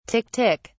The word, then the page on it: tick tick: British English